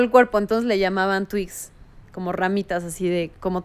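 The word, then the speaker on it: twigs: Es FKA Twix. Twix es como. Ah, no, Twix, de hecho se, llama, se dice Twix, se pronuncia así en inglés.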